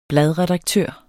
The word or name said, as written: bladredaktør